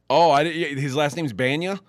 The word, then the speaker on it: Bana: Bana.